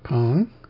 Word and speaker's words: Peng: It's P-E-N-G with an upward pitch change on the E, pronounced Pong.